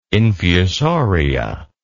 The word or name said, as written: infusoria